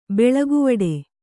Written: ಬೆಳಗುವಡೆ

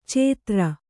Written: ಚೇತ್ರ

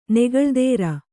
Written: ನೆಗಳ್ದೇರ

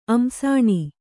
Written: ಅಮ್ಸಾಣಿ

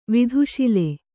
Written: ವತ್ಸಲ

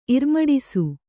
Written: ಇರ್ಮಡಿಸು

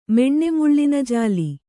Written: ಮೆಣ್ಣೆಮುಳ್ಳಿನಜಾಲಿ

